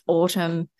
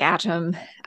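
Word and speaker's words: Atum: And despite the spelling, it's pronounced Autumn. But it looks like Atom, and I think half the people say it like that.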